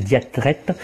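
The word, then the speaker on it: diatrète: Oui, alors c'est Diatrette, la prononciation est compliquée, sauf pour les gens de Saône-et-Loire qui ont le R facile.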